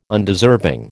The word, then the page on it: Undeserving: American English